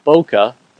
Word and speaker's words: bokeh: And bokeh, that's two syllables, is actually a little bit faster to say than ambient, which is three.